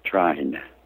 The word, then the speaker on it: trine: Trine, is it? – Trine.